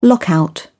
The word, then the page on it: lockout: British English